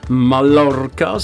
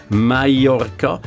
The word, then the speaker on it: Mallorca: Also nicht Mallorca, sondern Mallorca, nicht Bobadilla, sondern Bobadilla und nicht Sevilla, sondern Sevilla.